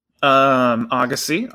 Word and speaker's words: Agassi: Agassi, obviously. Don't pronounce the Z.